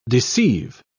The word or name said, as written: deceive